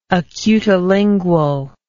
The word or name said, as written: acutilingual